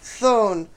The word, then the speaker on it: Chthon: C-H-T-H-O-N. Okay. But it's pronounced Thone, which is like a Greek concept of a cavernous hell-like environment.